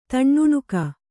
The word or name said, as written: ತಣ್ಣುಣುಕ